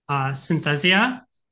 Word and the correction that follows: synesthesia: Synesthesia? – Yes. Okay. I always mix it up.